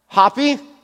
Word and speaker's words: Hapi: We have Hapi, or Happy, depending on how you want to pronounce it. It's H-A-P-I.